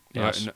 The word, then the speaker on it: Nous: Nous. No, Nos. I'm going to get this wrong a lot. Because it's spelt news, but it's not.